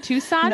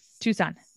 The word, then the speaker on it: Tucson: Yeah, I say this wrong. Tucson. – Nope. – Tucson. – Right, the second time.